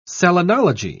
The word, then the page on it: selenology: British English